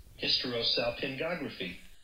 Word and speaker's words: hysterosalpingography: Um, this is how you pronounce it according to YouTube. Hysterosalpingography.